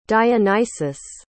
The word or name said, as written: dionysus